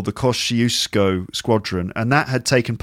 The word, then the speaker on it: Kościuszko: And it's difficult for me to say the name, so I've checked it out on Google Translate. This is how you say it. – Kościuszko.